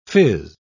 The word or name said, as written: fizz